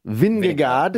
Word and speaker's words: Vingegaard: I'm going to go, this guy who's leading the Tour de France, Jonas Vingegaard.